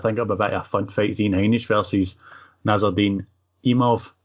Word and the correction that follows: Imavov: Imov? Did I say that right the first time? – Imov, is it not? What is it? Spell it? – Imavov.